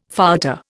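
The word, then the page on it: fouetté: Indian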